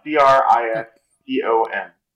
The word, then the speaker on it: Cristom: How do you spell that? – C R I S T O N. Uh, C R I S T O M. – Okay, Cristom. Okay.